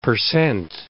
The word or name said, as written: percent